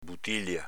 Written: butilia

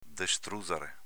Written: destruzare

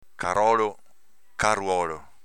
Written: carolo

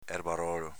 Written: erbarolo

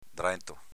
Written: drento

